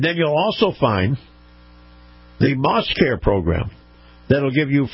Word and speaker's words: Maus: Now, this portion of the program brought to you by Moss Family Automotive and Moss Family Automotive's Moss Acura dealership. Now, Moss is spelled M-A-U-S.